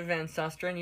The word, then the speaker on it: Van Susteren: Greta Van Susteren? Oh no. I've never said that out loud. Say this. – Greta Van Susteren, you said it right.